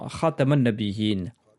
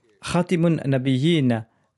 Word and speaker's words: Khatam nabiyin: Certes, ces deux prononciations sont possibles à dire Ali, mais je préfère la prononciation Khatam nabiyin car elle signifie le sou des prophètes. Khatimun nabiyin signifie celui qui met fin aux prophètes. Enseigne à mes enfants la prononciation Khatam nabiyin avec la lettre Ta.